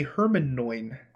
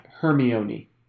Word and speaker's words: Hermione: No, when my mom read the first two Harry Potter books to me, she called Hermione Hermanoin. – Hermanoin. We called her Hermione. Because it's not a word that anyone knew about until those books. – Yeah. – Hermione.